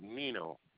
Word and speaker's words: Nenow: Last name is Nino. – Spell that last name, please. – N-E-N-O-W.